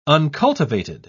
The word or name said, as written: uncultivated